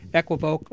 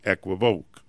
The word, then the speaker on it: equivoque: It means a particular thing, and so it doesn't matter that the correct pronunciation of this French word is equivoque. Okay, if you said equivoque in front of any Frenchman, they would die laughing.